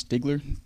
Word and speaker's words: Stigler: Am I saying his name right, Franz Stigler? – Yeah, yep. You are correct.